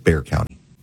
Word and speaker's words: Bexar County: I was just told Bexar County is Bear County in Texas. So Bear County in Texas. Why don't you spell it Bear if you want to pronounce it Bear?